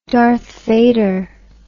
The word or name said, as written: Darth Vader